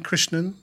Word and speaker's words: Krishnan: See, the A sound in a lot of Indian words and languages is not A or A, it's A. – Right. – Like Krishnan. N-A-N, Krishnan. That's how you say it.